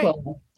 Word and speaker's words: Pacquola: Are we saying it right? – Yeah, you're nailing it. – Great. – Pacquola.